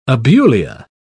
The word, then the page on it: abulia: British English